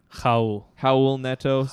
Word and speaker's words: Raul Neto: I mean, Raul Neto, or how do you say his name? – Howell Neto, but spelled Rawl.